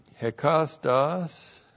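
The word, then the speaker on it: hekastos (ἕκαστος): And then the adjective hekastos is used as a substantive, a noun. Hekastos. That's H. E-K-A-S-T-O-S, hekastos.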